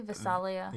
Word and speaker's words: Visalia: I'd say Visalia.